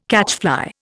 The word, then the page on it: catchfly: Indian